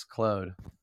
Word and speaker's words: Claude: The tool is called Poe, P-O-E. And the creative writing AI that I prefer is Anthropic's Claude. – Okay. – C-L-A-U-D-E. My French friend said I was saying it wrong, so hopefully I'm saying it right now.